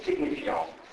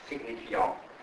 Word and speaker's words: signifiant: Signifiant, la T finale è muta perché fa parte della parola generale deposito, e quindi se non c'è una vocale dietro non va letta in francese, e quindi questa parola in francese si legge signifiant, signifiant. La N nasalizza la A e la T non si legge proprio, quindi signifiant.